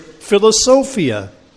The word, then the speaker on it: philosophia: A word with different connotations than the Greek word philosophia, love of knowledge. Philosophia, I think the accent's on the penultimate syllable in Greek.